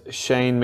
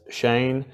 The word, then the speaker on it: Cheyne: Now, if you're not sure who I am, my name is Shane McMahon, C-H-E-Y-N-E. Yes, I am a male. Yes, it's strange spelling and it is pronounced Shane, named after a famous surfer that nobody has heard of.